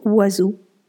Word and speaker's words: oiseau: Donc pour la petite histoire, il existe un mot en français où aucune lettre ne se prononce comme elle le devrait. C'est le mot oiseau. En effet, dans ce mot, on n'entend ni le son O, ni le son I, ni le son S du S, ni le son E, ni le son A, ni le son U.